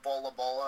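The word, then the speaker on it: Bol Bol: Or Bola Bola, of course. – Yeah, right. – That's how it's said. – Yeah, Bola.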